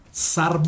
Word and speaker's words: Tsar: Entonces escriban por favor los siguientes nombres: Sar Bomba. – ¿Cómo se escribe Sar? – T-S-A-R.